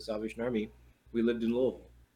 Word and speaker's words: Louisville: And yes, that is how it's pronounced. – Louisville. – Louisville. – Louisville. – Louisville. It's very entertaining to try and talk to people that don't understand that. Because they'll say Louisville or Louisville.